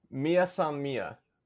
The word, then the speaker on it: Mia san mia: You mentioned earlier about the Mai Si Mai. How is that pronounced again? – Mia San Mia.